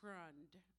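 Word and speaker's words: frēond: It's an old English word, and it's pronounced frund, F-R-E-O-N-D.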